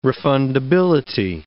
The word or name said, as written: refundability